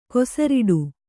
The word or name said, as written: ಕೊಸರಾಡು